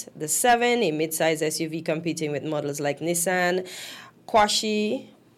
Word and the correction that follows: Qashqai: Did I say that right? – Qashqai. – Yes.